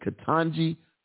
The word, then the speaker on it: Ketanji: Is it Ketanji? That's it. Ketanji Brown Jackson.